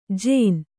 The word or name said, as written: ಜೇನ